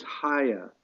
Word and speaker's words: Haya: The project name is Haya. It rhymes with Gaia, like our planet Earth, and it stands for Healing Art Installation as Home.